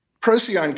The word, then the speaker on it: Procyon: Procyon comes from the Latin. There are no soft C's in Latin. So the actual pronunciation from, you know, a long time ago, and it's been modernized, and I'm okay with that, but the actual pronunciation is Procyon.